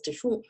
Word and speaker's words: DeChant: So it's pronounced as DeChant, and I've had this happen to me so many times.